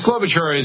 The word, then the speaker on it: Klobuchar: Klobuchar, yeah.